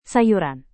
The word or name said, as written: sayuran